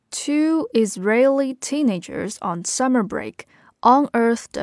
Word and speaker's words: Israeli: 这个地方大家首先注意一下这个单词的读音，它就是 Israeli。 Israeli 跟这个国家以色列 Israel 他们的重音是不一样的哈。